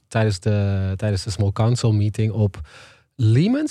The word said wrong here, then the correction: Lyman: Zeg ik dat goed? Lyman? – Lyman volgens mij, ja.